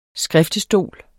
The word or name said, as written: SKRIFTESTOL